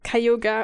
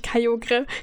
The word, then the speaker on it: Kyogre: Ja, es ist Kayoga und nicht Kyogre, wie ich immer dachte.